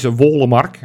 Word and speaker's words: Wollemark: We hebben natuurlijk meteen met Sjoerd gevraagd hoe we het uitspreken. Hè? Dus het is Wollemark.